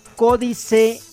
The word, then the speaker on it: Códice: Códice Nautilus, con acento en la O, Códice Nautilus.